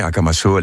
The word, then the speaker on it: Akamasoa: Vous avez transformé une ancienne décharge en une cité de la dignité qui s'appelle Akamasoa. Ça va, je le prononce bien ? – Oui, Akamasoa, les bons amis, les amis fiables.